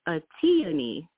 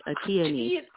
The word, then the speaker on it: Atyani: Yeah, my name, you're doing really well, and even better is Atyani, like a peony, Atyani.